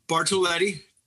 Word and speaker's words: Bartoletti: Did I pronounce that right? – Bartolotti. – Bartolotti. Excuse me.